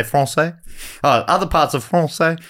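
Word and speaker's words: Français: Over the following centuries, they happened over other parts of En Francais. Is that right? Am I saying that right? – Yes, yep, correct.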